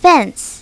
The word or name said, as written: fence